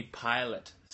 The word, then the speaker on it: Pilate: Pilate. I always say Pilates. Yeah, because it's spelled the same. They do Pilates. That's what I thought that exercise was back in the day. It was invented by Pilates back in Jesus' time. – Oh, Pilate.